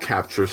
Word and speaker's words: Kapchr: So you can control, you can have a mixer attached, you can have external microphones attached, and it's called Capture, Stephanie. It's spelled K-A-P-C-H-R.